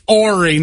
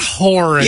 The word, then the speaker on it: Horry: And now we're getting corrected that the H is silent. – Hey, y'all, it's Horry, not Horry.